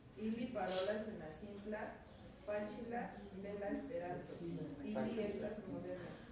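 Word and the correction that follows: facila: Fatsila, gracias a Angélica. Estamos 3-2. ¿Cómo se pronuncia, Carlos? Fatsila. Fatsila.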